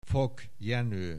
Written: Fock Jenoe

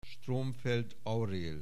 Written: Stromfeld Aurél

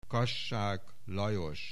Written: Kassák Lajos

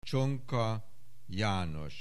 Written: Csonka Janos

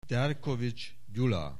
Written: Derkovits Gyula

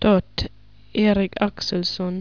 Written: Totterik Axelson

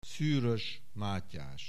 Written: Szűrös, Mátyás

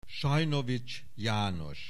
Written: Sajnovics János

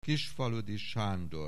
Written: Kisfaludy Sándor

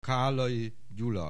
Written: Kállai Gyula

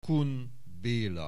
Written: Kun Béla

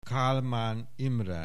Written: Kálmán Imre

